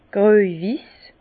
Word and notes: gröj-wiss: Bas-Rhin; Herrlisheim

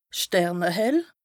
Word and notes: sternehell: Bas-Rhin; Strasbourg

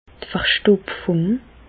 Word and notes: d' Verstoptfùng: Alsatian; Bas-Rhin; Reichshoffen

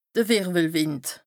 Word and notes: de Wìrwwelwìnd: Bas-Rhin; Strasbourg

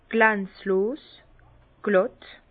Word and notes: glànzlos, glàtt: Bas-Rhin; Herrlisheim

